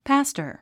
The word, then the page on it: pastor: pǽstə r; パスタァ